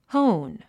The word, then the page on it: hone: hóun; ホウン